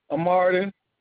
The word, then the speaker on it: Martin: My name is Malcolm Martin. – Can you repeat the last name for me? – Martin. – Can you spell that for me? – M-A-R-T-I-N. – M-A-R-T-I-N.